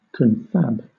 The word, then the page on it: confab: British English